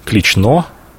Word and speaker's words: Клично: Здесь каждый расскажет вам легенду, что первоначально город Осташков был не здесь, а он находился на нынешнем острове Клично. Именно так произносится это слово с ударением на последний слог.